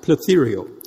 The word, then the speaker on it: phtheiro: Now, the word corrupted in the Greek is spelled P-H-T-H-E-I-R-O, like plethereo.